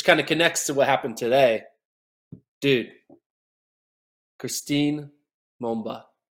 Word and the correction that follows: Mboma: I said her name wrong. Christine Mbote. Mboma? How do I say her last name? – Yeah, I think it's Mboma. – Mboma.